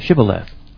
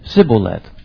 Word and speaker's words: Shibboleth: And if he said no, then they would say to him, "Now say Shibboleth." But he could only say Sibboleth, for he could not pronounce it correctly.